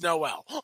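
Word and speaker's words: nolle: But and it is Noel. Just, you just destroyed it. No, it. Mm-hmm. Well, it's spelled like your name, so it's Noel.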